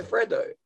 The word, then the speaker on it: Freddo: Maybe it's I pronounce it. It's a Freddo. F R E D D O.